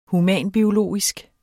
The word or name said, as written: humanbiologisk